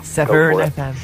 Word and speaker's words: Severn: How he said Severn, he said it properly. – Hi, this is Derry from EMF, and you're listening to Severn FM. – Severn FM.